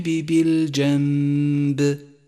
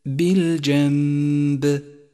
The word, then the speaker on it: بالجنب: خامسا، لاحظ حكم الإقلاب في قوله تعالى بالجنب والصاحب بالجنب، حيث تنقلب النون الساكنة إلى ميم إذا جاء بعدها باء، ويسقط أثر النون الساكنة من اللفظ تماما، ولذلك لونت بالرمادي في مصحف التجويد إشارة إلى عدم نطقها، ويستعاض عنها بميم كتبت فوقها. لونت الميم باللون الأخضر لأنها تحتاج إلى غنة زمنها حركتان، فيصير اللفظ هكذا: والصاحب بالجنب، بالجنب.